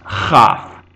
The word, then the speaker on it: Kaf: Kaf, without the dagesh, which is the dot in the middle, is pronounced chaf, as a kh sound, a hard k, chaf.